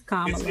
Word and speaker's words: Kamala: Kamala. I don't know if I said it right the first time. – It is. It's Kamala.